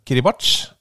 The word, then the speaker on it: Kiribati: uh, jo, nei, bare Kiribati. Det, det, ja, det, er kjempebra. – Det er veldig bra med korrupsjon. Uh, Kribati, eh, som visstnok da uttales eh, utilfredsstillende Kiribach.